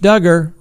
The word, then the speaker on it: Duggar: Josh Duggar, rhymes with hugger,